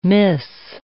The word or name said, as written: miss